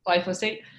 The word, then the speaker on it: glyphosate: glyphosate? Glyphosate.